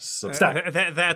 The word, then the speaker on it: Substack: The emphasis is on the stack. Substack.